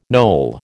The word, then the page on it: Noel: American English